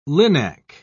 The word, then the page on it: linac: British English